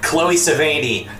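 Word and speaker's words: Chloë Sevigny: Also, Chloe Sevigny, however you pronounce her name, would have been the Amy Adams character. – Chloe Sevigny.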